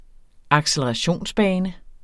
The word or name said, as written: accelerationsbane